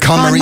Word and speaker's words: KonMari: The KonMari method.